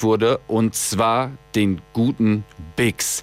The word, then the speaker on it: Biggz: Ich habe beim letzten Mal leider, weil ich nicht wusste, wie er richtig ausgesprochen wird, gesagt, sein Name ist Big Z. Er wird geschrieben B-I-G-G-Z und es wird Bix ausgesprochen.